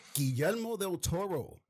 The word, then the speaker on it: Guillermo del Toro: And Guillermo del Toro.